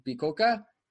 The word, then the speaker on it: Bicocca: And you are from the University of Milano Bicocca. Am I saying that right? – Okay. Yes, that's correct.